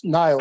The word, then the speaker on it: Niles: were you in Niles, Ohio? Did I say that right? – Yes, Niles.